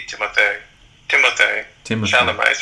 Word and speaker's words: Timothée Chalamet: It's a good question, because according to the accent on the first A, it would be Timothée Chalamet.